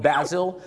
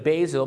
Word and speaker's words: basil: ออกเสียงว่า basil หรือ basil